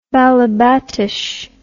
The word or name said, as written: balebatish